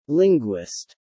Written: Linguist

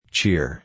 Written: cheer

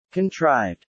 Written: contrived

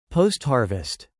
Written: post-harvest

